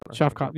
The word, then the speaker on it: Shavkat: Shavkat.